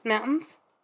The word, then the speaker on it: Mountains: That word has a T in it. Mountains. Sorry to all our American listeners, but the amount of words that they pronounce that like don't have, that has a T in it that they don't pronounce with a T.